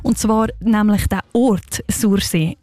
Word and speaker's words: Sursee: Einmal so aussprechen, wie ein Einheimischer oder eine Einheimische das würde. Und ich komme ja selber auch von dort und zeige ich es euch jetzt schnell mal vor. Sursee. Nicht anders, Sursee.